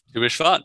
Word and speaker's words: Tu Bishvat: Help me with the pronunciation. Tu Bishvat? – Tu Bishvat, yeah. Good job.